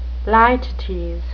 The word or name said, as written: litotes